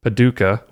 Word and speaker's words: Paducah: And she said, I generally watch on YouTube, but was getting caught up listening on my drive home from Quilt City, Paducah, right? – Yes, you said it right.